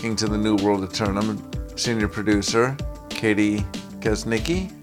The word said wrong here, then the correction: Kesniski: Oh, I'm sorry, Kesniski.